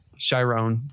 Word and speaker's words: Chiron: You love, Jerome, and am I saying his name wrong? – Chiron. – Yeah. You love Chiron and you love, Juan, and you love them together.